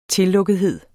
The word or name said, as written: tillukkethed